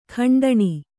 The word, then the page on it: ಖಂಡನೆ: kannada